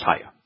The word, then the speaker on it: haya: The verb does, and there isn't any question that anybody has that haya is pronounced haya. And it in first person would be e-haya.